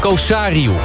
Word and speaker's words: Kosariu: Onder andere opgestemd door Remco Mulder en Alexandra Kosui. Oh, spreek nou toch goed uit. Kosariu. Je wil mensen hun namen goed uitspreken.